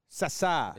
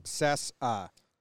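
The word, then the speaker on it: Cessa: Sessa. – Sessa. – Is the pronunciation. – That's how you pronounce it? Sessa.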